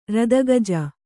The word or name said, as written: ರದಗಜ